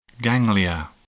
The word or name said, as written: ganglia